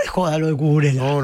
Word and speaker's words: Cucurella: Y encima nosotros nos reíamos porque yo le decía Cucurella y Espina le decía Cucurella, que creo que es la pronunciación lógica. – Correcto, sí, por ella. Español. No es italiano, sino español.